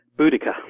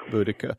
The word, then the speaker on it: Boudica: Boudica. – Boudica. – She used to be called Boadicea, but that was a Victorian mistranslation. – when is she in history? She's real. – Well, yeah. – Boudica is kind of fascinating because she's one of these things we don't understand.